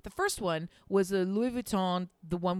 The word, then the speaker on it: Louis Vuitton: Louis Vuitton is how you say it.